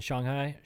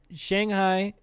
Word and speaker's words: Shanghai: Man, so I have a story from when I was in China, in Shanghai. So Shanghai is how Americans say that city's name in China. But if you really want to be mindful and say it correctly, it's Shanghai.